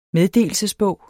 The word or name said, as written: meddelelsesbog